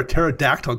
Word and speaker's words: pterodactyl: Why can't you hear a pterodactyl go to the bathroom? – Because the P is silent. – I would have also accepted the sound is extinct, but yes, the P is indeed silent.